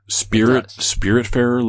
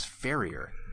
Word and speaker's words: Spiritfarer: Spiritfarer looks awesome. – Can you spell that? – One word. Spirit Farer. F-A-R-E-R. – Farer. – Farer. – I thought it was Farrier.